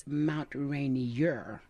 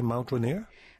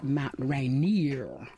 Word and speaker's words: Mount Rainier: The correct name, if you're an old timer, is Mount Rainier. And I always say it's rainier in Mount Rainier than anywhere else on earth.